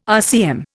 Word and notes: RCM: Indian

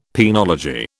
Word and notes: penology: British English

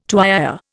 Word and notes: tuyère: Indian

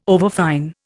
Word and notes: overfine: Indian

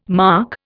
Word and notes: marc: Indian

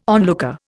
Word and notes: onlooker: Indian

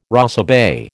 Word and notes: rosebay: American English

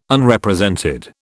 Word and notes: Unrepresented: British English

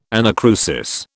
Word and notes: anacrusis: British English

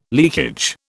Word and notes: leakage: British English

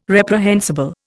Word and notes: reprehensible: Indian

